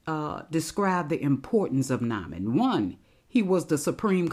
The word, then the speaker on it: Naaman: Now, uh, let me share some information uh, about Naaman or Naaman. His name should be spelled the same in no matter what version of the Bible you have N A A M A N, but my studies show that it is pronounced Naaman.